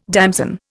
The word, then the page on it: damson: Indian